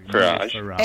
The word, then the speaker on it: Farage: Farage. – F-A-Rage, yes. – Yeah. Oh, nice. – That's right. Do you know why it's not pronounced like garage? – No.